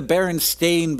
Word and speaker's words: Berenstain: Well, I always say Berenstein Bears. I don't know if that's correct. I know that it's spelled with an A, but I've always pronounced it with an E because that's the way I've heard it pronounced. What is correct? – I think that we were raised in a Berenstein Bears household. That's just what our parents did. But it is indeed the Berenstain Bears, and it has always been the Berenstain Bears.